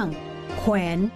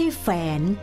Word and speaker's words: แขวน: ่คำที่มีวอแหวนเป็นอักษรควบต้องอ่านออกเสียงทั้งสองตัวเป็นเสียงควบเช่นคว้างไม่ใช่ฟ้างแขวนไม่ใช่แฝน